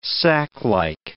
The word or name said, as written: saclike